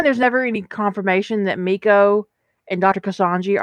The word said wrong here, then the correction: Kusanagi: Kusanagi, ever how you say it? How you say it? – I say Kusanagi, but... – Kusanagi.